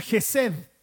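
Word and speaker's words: Gesed (חסד): De hecho, esta, frase que utiliza, su fiel amor, su fiel amor, viene de una palabra hebrea que se pronuncia Gesed.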